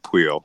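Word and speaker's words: Pouille: Well, I tracked down a French commentator during Roland Garros last year to get a French pronunciation, and he said Puel. So I've been saying Puel too. So we'll say Luca Puel, but it's Puel. It also is spelled like Pui, or Pool. You could say it a lot of ways, but I like Puel.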